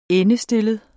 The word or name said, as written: endestillet